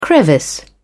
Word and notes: crevice: British English

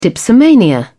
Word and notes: dipsomania: British English

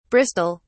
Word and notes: bristol: British English

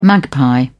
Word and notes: magpie: British English

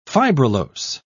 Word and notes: fibrillose: British English

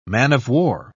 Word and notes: man-of-war: British English

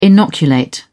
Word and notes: inoculate: British English

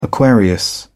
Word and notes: aquarius: British English

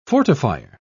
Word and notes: fortifier: British English

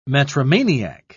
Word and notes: metromaniac: British English

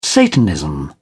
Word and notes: satanism: British English

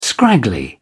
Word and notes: scraggly: British English

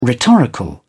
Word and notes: rhetorical: British English

